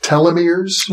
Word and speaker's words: telomeres: One of the biomarkers that you reference in your book, I think it's pronounced telomeres.